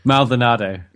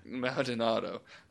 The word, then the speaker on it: Maldonado: Maldonado. – Maldonado.